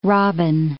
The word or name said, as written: robin